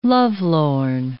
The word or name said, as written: lovelorn